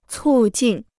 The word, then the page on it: 促进: cù jìn